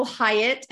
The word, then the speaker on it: Hiatt: The best place is my website and it's April Hyatt, and Hyatt is H-I-A-T-T. But if you happen to spell it wrong with a Y, I actually own both domains and one just points to the other.